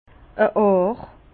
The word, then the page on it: e Àr: Bas-Rhin; Herrlisheim